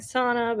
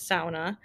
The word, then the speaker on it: sauna: In the United States, we're very used to saying sauna, but it is sauna.